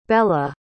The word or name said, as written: bella